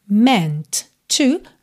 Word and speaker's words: meant to: Seguindo, I believe, atenção à pronúncia dessa palavra, believe, it's meant to be, meant to, você junta num T só,